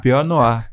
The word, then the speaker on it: Pinot Noir: Ele é 70% uvas Chardonnay, falei certo? – Chardonnay. – É, e 30% Pinot Noir? – Pinot Noir.